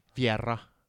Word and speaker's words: Vieira: Vieira har jag hört, lärt mig att det uttalas på portugisiska. Med väldigt mycket betoning på R-et. – Vieira.